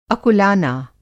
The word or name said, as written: अकुलाना